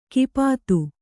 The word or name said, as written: ಕಪಿತೆ